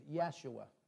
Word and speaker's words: Yeshua: Says, it has been taught on the eve of Passover they hanged Yeshua. That's the Hebrew pronunciation of Jesus.